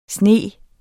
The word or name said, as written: sniger